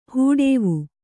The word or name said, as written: ಹೂಡೇವು